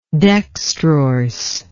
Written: dextrorsely

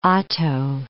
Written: Otto I

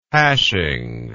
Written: hashing

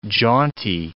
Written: jaunty

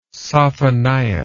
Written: sophonias

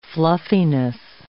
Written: fluffiness

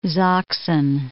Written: Sachsen

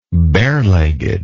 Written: barelegged

